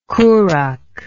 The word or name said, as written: Kurukh